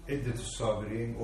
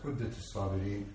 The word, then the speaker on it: عدة الصابرين: But here, Ibn Qayyim rahimahullah, one of the Imams of Ahl Sunnah, has written this book, Iddat al-Sabirin, or it could be pronounced Uddat al-Sabirin, wa Dhuhirat al-Shakirin.